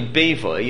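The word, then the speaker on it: Bevai: By the way, the name is not Bibi. People often, right, pronounce it Bibi. The name is really Bevoi.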